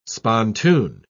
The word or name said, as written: spontoon